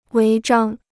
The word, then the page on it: 违章: wéi zhāng